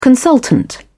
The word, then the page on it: consultant: British English